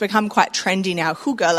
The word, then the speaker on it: hygge: Lara got me onto this book called I forgot to check the pronunciation. It's Danish. Holy Hygge. Hygge. It's a Danish word. It means kind of like intentional, thoughtful coziness. Is that close? Pretty good.